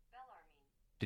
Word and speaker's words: Bellarmine: see if you can hear it to get the pronunciation right. Bellarmine. Did you hear that? – Bellarmine.